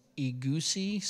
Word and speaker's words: egusi: egusi soup. Did I say it right? Yes, all right.